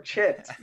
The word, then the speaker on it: chit: Oh yeah, well, it's that Sanskrit word chit.